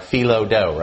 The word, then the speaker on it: Philo: P-H-I-L-O. Or Philo-do, right? But Philo is how we're going to pronounce it.